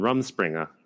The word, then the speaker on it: Rumspringa: And then they meet up, with another van, and it's two Amish people on Rumspringa. Am I pronouncing that right? – Yes.